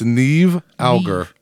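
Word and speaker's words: Niamh Algar: Actually, it's Neve Auger. Neve. – If you see how it's spelled, I would not have got Neve out of that, but I'm one to talk with, you know, my last name.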